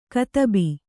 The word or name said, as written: ಕದುಬು